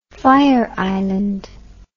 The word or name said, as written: fire island